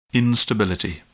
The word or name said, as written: instability